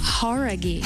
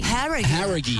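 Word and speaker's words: Jauregui: Horaghi. Who é? – Horaghi. – Horaghi. – Horaghi. Então a gente tava falando errado, cara.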